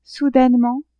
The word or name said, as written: soudainement